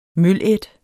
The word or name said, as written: mølædt